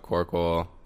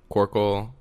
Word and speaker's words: Corkill: It's just my name, Alec Corkle. And then I have a Vin. – Can you spell that? I have a trouble with usernames. – It's Alec, A-L-E-C, Corkle, C-O-R-K-I-L-L.